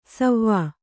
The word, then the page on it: ಸವ್ವಾ: savvā